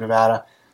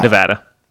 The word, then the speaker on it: Nevada: By the way, how do you guys pronounce this? I saw a bumper sticker that advocated for a particular pronunciation of Nevada. I also guess my question will be half answered by whomever reads this email. Nevada. – Nevada. – Yeah. Not Nevada.